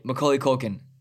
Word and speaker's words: Macaulay Culkin: Macaulay Culkin. – Did, did, I get the name right? – Yeah. Mm-hmm. mm-hmm.